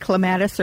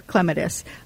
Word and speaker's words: clematis: It's like clematis or clematis. You can do either one. In the United States, normally we are emphasizing the second syllable. In Britain, in England, it's the first syllable.